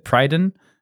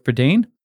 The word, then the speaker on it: Prydain: No one taught me how to say it, and I said Prydain, but that obviously sounds dumb, so I'd go with Prydain.